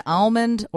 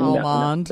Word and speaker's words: almond: Let me bring almond. – That up. you can drop the L or not, and then the vowel quality can be aw or ah.